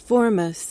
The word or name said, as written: formous